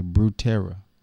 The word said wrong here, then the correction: Butera: Yeah, Drew Butera.